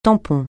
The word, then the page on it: tampon: francais